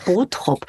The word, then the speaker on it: Bottrop: Wir betonen eher so ähm, die erste Silbe Botrop. Bot, Botrop.